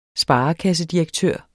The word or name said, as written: sparekassedirektør